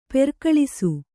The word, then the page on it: ಪರಕಲಿಸು: parakalisu